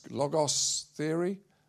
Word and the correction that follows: Logotherapy: Am I saying it right? Logotherapy.